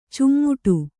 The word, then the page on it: ಚುಮ್ಮುಟು: cummuṭu